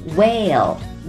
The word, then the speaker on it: whale: 说 whale, 鲸鱼这个单词的时候，别忘了字母 L 的发音方式。对了，就是咱们已经说过很多次的，把舌尖放到上齿背后顶住，喉咙发出声音。 Whale, whales. Mammals, M-A-M-M-A-L-S, mammals, 就是咱们说的哺乳动物。